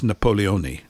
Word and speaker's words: Napoleone: And I love the way the Italians say his name. It's Napoleone, which is, I quite like that.